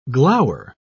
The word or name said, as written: glower